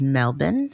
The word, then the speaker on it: Melbourne: The pronunciation is Melbourne. So like the O, the R, and the E are all silent. It's Melbourne. – It's not Melbourne.